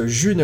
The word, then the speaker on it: Ğ1: et qu'on prononce June la June.